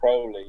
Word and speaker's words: Crowley: Crowley.